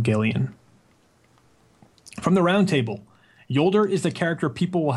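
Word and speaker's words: Jolder: Yes, it is pronounced Yolder, or at least all the writers pronounced it Yolder.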